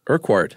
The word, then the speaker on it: Urquhart: Another BBC guy named Nicholas Witchell organized Project, how do you pronounce that, Urquhart? – I was going with Urquhart. – Oh, Urquhart. I like that. – I do, too. – Silent H. – Yeah. But also the qua.